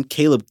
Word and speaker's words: Kaileb: Caleb, K-A-I-L-E-B.